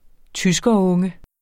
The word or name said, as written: tyskerunge